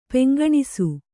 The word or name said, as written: ಪಿಂಕಣಿಸು